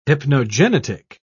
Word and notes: hypnogenetic: British English

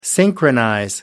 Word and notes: synchronize: American English

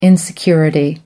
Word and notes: insecurity: British English

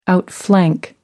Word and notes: outflank: American English

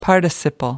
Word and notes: participle: American English

